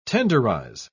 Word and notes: tenderise: British English

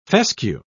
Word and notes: fescue: British English

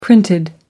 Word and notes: printed: American English

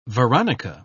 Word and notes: veronica: British English

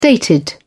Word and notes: dated: British English

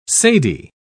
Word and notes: cedi: British English

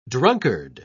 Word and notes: drunkard: British English

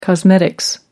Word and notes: cosmetics: British English

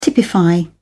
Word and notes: typify: British English

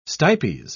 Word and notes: stipes: British English

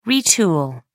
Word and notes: retool: American English

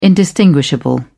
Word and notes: indistinguishable: British English

